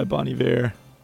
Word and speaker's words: Bon Iver: Hey, we had Nico Case Rec and then From by Bon Iver, which I pronounce in the French style, even though he's from Minnesota. Yeah.